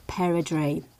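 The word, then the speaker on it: perdrix: And if the partridge really is French, then it would be called un perdri, correctly pronounced perdri.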